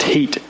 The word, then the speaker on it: Hit: The town was called Heat. It's spelled H-I-T, but it's pronounced Heat.